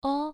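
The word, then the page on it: ou: tone 1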